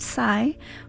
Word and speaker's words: SY3: This is her latest track, and then after that was another LA-based artist, spelled S-Y-3 and pronounced Sai, with their track Tell Me.